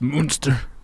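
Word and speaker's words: Muenster: So there's like provolone and Swiss and, you know, cheddar and, you know, what have you, mozzarella, some monster, I believe. Is that how it's pronounced? – I believe it's pronounced Munster. – Okay, Munster. All right.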